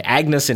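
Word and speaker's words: Agnès: Am I pronouncing that right? Agnes in French.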